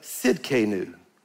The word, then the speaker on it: Tsidkenu: And it's pronounced, the T is silent, Jehovah Tsidkenu.